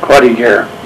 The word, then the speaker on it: Croix de Guerre: and I probably mispronounced it, the Croix de Guerre. – Croix de Guerre.